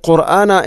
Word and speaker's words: قرآن: ഇവിടെ ഖുർആന എന്ന പദത്തിൽ പ്രത്യേകിച്ച് നമ്മൾ ശ്രദ്ധിക്കേണ്ടത്, എപ്പോഴും നമ്മൾ പറയുന്നൊരു വാക്കാണത് ഖുർആൻ. നമ്മൾ പലപ്പോഴും ഖുർആൻ ഖുർആൻ എന്നാണ് പറയുക. അപ്പോൾ ഖുർആന ഖുർആന, ഖാഫിൻ്റെ ഉച്ചാരണമെങ്കിലും പ്രത്യേകിച്ചും ശ്രദ്ധിക്കണം. അതൊരിക്കലും കാഫിനെ കൊണ്ട് പറയരുത്.